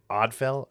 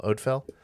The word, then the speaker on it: Odfel: It's Odfell, O-D-F-E-L, Odfell.